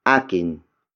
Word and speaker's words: akin: And that is akin. Akin. Akin. Akin is spelled as A-K-I-N.